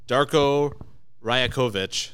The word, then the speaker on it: Darko Rajakovic: Let's say his name. – Darko Rajakovic. – You got it. I'm glad you said it best.